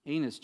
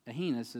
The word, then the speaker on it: Aeneas: And Peter said unto him," Aeneas, Jesus, Aeneas is how it's pronounced, darn it, "Jesus Christ maketh thee whole.